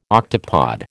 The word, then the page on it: octopod: American English